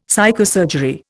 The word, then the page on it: psychosurgery: Indian